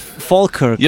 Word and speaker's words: Falkirk: Falkirk. – You're saying it right. You're saying it correct.